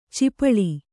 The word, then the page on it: ಚಿಪಳಿ: cipaḷi